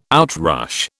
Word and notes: outrush: British English

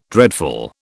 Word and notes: dreadful: British English

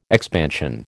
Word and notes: expansion: American English

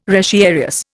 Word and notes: retiarius: Indian

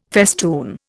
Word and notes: festoon: Indian

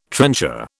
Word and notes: trencher: British English

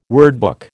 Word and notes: wordbook: American English

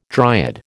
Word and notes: dryad: American English

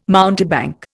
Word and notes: mountebank: Indian

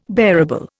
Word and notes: bearable: Indian

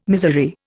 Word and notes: miserere: Indian

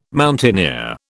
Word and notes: mountaineer: British English

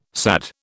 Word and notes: SAD: British English

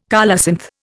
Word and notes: colocynth: Indian

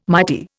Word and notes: mighty: Indian

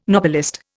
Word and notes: Nobelist: Indian

